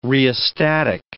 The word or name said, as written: rheostatic